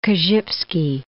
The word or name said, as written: Korzybski